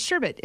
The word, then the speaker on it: sherbet: Is it sherbet or sherbet? No, but how would you say it? – Oh, it's sherbet. It's spelled S-H-E-R-B-E-T.